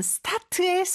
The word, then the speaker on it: start: S 다음에 오는 T는요, 보통 강세가 있는 자리에 있을 때는 이렇게 된소리를 해주면 훨씬 더 발음하기가 쉽죠. 그래서 I start 이렇게 하면 되고요. 마지막 T 발음은 만약에 잘안 된다 그러면 하려고 하는 듯이 그냥 시옷 받침에 느낌을 주시면 돼요. I start 이렇게요.